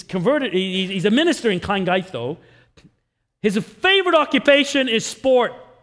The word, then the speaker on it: Llangeitho: Llangeitho. It's spelt L-L-A-N-G-E-I-T-H-O. But it's when you get two L's together, it's a close sound. Llangeitho.